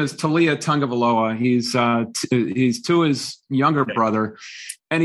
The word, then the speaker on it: Tagovailoa: I didn't know how to pronounce Tagovailoa until probably last year, but he still can't pronounce it.